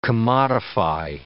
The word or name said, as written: commodify